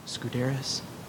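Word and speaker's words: Skouteris: How do you distinguish Scuderis? And I'm sorry if I'm butchering the pronunciation. – Scuderis? – Yeah, it's S-K-O-U-T-E-R-I-S.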